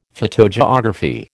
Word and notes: phytogeography: American English

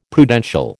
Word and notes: Prudential: American English

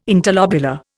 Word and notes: interlobular: Indian

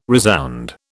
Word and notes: resound: British English

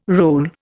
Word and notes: role: Indian

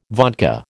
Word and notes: vodka: American English

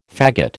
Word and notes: faggot: American English

